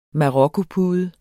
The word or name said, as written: marokkopude